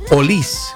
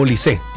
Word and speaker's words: Olise: Erling Haaland, la forma correcta, me fui a como la forma correcta de referirse a Michael Olis, no Olise. Eso también durante el Mundial me fui tomando como es ir buscando bien. Michael Olis se pronuncia.